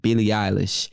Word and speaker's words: Billie Eilish: So what is a cop for me that I really, really want is, let me make sure I'm pronouncing this name right, Billie Eilish. Is that correct? – Yes.